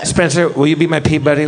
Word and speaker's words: Peabody: It's spelled P-E-E-B-U-D-D-Y is how it sounds. – Yes, Peabody. That's the one. – Peabody.